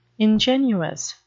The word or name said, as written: ingenuous